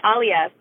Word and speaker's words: Aaliya: Yeah, really close. It's actually Aaliya, but so happy to be here.